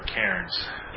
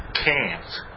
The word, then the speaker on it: Cairns: Actually, I'm told I'm not supposed to pronounce the R in Cairns. It's supposed to be Cairns.